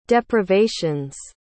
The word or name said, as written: deprivations